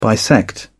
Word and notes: bisect: British English

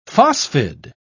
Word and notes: phosphide: British English

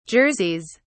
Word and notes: jerseys: British English

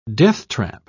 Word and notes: deathtrap: British English

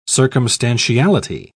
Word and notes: circumstantiality: British English

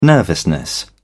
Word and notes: nervousness: British English

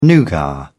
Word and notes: nougat: British English

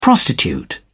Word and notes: prostitute: British English

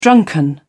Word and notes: drunken: British English

